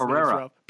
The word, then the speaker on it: Pereira: I thought that Pereira, I don't know how to say it. Terrible with Portuguese Pereira. – Pereira, there it is, Pereira.